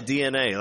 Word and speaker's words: DeNA: Yeah, DNA. Like, I remembered it. It's, yeah, it's spelled weird, but it's like a real thing in life.